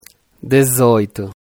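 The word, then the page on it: 18: Brazilian Portuguese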